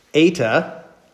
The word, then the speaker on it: eta: Eta, that's kind of like a long E. Like, so like the word I ate, A, A. All right, so it's like a long E. Well, it's transliterated with an E, but it's actually more like a long A sound for us.